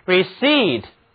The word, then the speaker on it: received: 这个词中字母 p 不发音，而字母组合 e-i 读作长元音 e，重音放在后面一个音节上，请跟我读 received，收到接到。